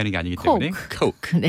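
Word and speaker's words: Coke: 네, 콜라 발음 제대로 잘하셨는데 콕, 이게 코크 맞는데.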